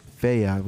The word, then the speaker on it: Fayyad: Fayyad.